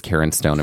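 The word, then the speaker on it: Cairn Stone: This is spelled C-A-I-R-N, and it sounds like a woman's name.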